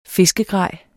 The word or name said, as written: fiskegrej